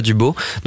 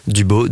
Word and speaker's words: Dubau: Son profil c'est Joshua Dubo. Donc Joshua, ça s'écrit Joshua, donc J O S H U A Dubo D U B A U.